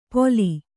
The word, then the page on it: ಪೊಲಿ: Kannada